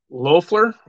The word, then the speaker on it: Loeffler: Is it Laffer? I don't know how to pronounce that last name. Loeffler? – Loeffler? – Yeah.